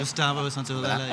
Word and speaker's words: Gustavo Santaolalia: Gustavo Santaolalia. Is that how you pronounce it? – Gustavo Santaolalia, yeah.